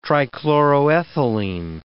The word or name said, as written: trichloroethylene